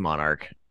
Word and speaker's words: MNRK: Monarch is heavy, how you pronounce it. A lot of people say MNRK, but it is Monarch. I have to correct people constantly.